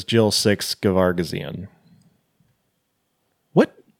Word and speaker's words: Gervagizian: One is from Jill Gervagizian, I think is how it's pronounced. – I did like one of the like, how do you pronounce this name things, and they said Gervagizian, because there is that other G in there. There's a second G.